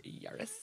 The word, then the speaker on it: Yaris: It's a Yaris. – It's pronounced Yaris.